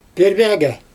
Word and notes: pörbjAgA: Xyzyl

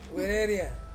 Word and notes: wereria: Ansus

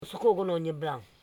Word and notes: sokogolonyebla: so.ko.go.lo.nye.bla

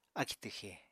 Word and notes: akì twxè: apatani

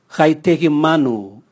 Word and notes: gaitegi manu: Raga

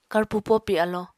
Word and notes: karpo-popi alo: Apatani

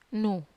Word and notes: nu: Wamesa

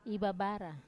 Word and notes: ivabara: wamesa

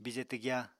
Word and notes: bije twgya: Apatani

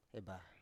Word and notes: ebah: Biak

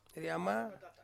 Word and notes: rama: Ansus

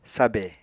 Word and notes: sabè: Apatani

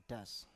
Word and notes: das: Biak

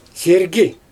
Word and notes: sergi: Xyzyl

